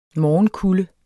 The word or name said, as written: morgenkulde